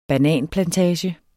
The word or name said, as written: bananplantage